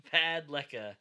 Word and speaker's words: Padalecki: So, Supernatural star Jared Padalecki, or however you say his name. – Padalecki.